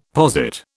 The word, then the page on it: posit: British English